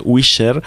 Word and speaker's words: Whisher: que así es un poco difícil de pronunciar, W-H-I-S-H-E-R, Wisher.